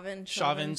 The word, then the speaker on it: Chauvin: Chauvin.